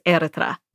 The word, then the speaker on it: Eritrea: Eritrea is pretty close, but the correct way to say it is Eritrea.